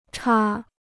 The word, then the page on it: 叉: chá